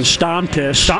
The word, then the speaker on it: Stammtisch: Am I even saying it right? Number one. – Stammtisch. Stammtisch. – All right. – You have to sound angry when you say it.